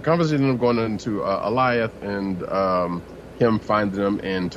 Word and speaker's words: Alioth: Eliath. Eliath. Like okay. Goliath. Like Goliath. Yeah, Goliath without the G.